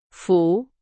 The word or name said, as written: 福